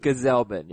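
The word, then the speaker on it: Gsellman: I think he only threw it like 8 times yesterday, but this was definitely a relieving start for Robert Gazelman for me. – Gazelman. – Gazelman. – Gazelman, yeah. Hard G.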